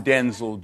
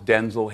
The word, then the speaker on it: Denzel: Because, wait a minute, because my name's not pronounced Denzel. My name's pronounced Denzel. – Oh. – I'm Denzel Jr. My father's Denzel Hayes Washington Sr. I'm Denzel Hayes Washington, Jr. My mother would say, Denzel, and we both show up.